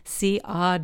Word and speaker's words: Siodmak: On the back of his coat, he had chalked out the phonetic pronunciation of the three syllables in his name with dash marks, Siodmak.